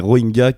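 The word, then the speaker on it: Rohingya: Tu nous fais un point sur les Rohingyas, je le prononce bien Ouais, c'est ça, c'est les Rohingyas.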